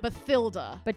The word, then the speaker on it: Bathilda: Bathilda. – It's a silent H.